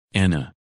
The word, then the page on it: Anna: English